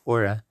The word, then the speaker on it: horas: Second is segunda, which is like Spanish too that has it, and hour is horas. But you don't pronounce the H, so it's just aura. without the H sound, which is similar to Spanish, but not quite.